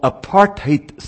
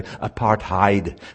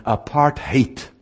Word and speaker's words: apartheid: I was raised in what is called apartheid South Africa. That's the proper way to pronounce the word, although in the United States most people say apartheid, thinking it's uh, German. It's not German, it's Afrikaans. But it's an, if you hear it properly, apartheid.